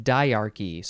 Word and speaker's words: dyarchy: and I'm going to have to look ahead to get the pronunciation, diarchy, D-Y-A-R-C-H-Y, diarchy.